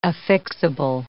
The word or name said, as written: affixable